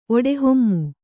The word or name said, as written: ಒಡೆಹೊಮ್ಮು